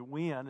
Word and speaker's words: Wynn: But uh, Sammy's been coming here for a while, and, and Sammy Wynn is kind of the way you, you pronounce it.